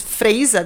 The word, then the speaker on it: Freisa: É Freisa mesmo, gente. É assim que se pronuncia.